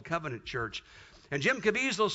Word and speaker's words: Caviezel: How do you pronounce that? Caviezel. Yeah, Jim Caviezel.